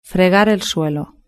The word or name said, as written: fregar el suelo